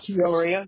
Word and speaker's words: Peoria: Is that how you say it, Peoria? I don't want to miss it. Yeah, Peoria. Yeah, you were right.